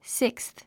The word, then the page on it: sixth: síksθ; スィックスス